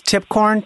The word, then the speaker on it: Tipcorn: Tipcorn, T-I-P-C-O-R-N. – Okay. T-I-P-C-O-R-N. – Yep, tip like the top of a corn. Tip of a corn. Tip corn.